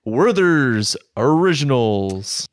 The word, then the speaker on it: Werther's Originals: Werther's Originals.